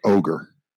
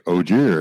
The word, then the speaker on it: Ogier: The other thing I will say is I never really even, because in the books it's spelled O-G-I-E-R. So I always said it as Ogier. I never even thought to say it like ogre. And then Rand says, oh, you're an ogre. And he's like, Ogier. And I'm like, oh, damn. Okay, I see that now.